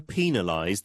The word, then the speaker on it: penalize: 영국식 발음 penalize, 그리고 북미권에서는 penalize라고도 발음할 수 있어요.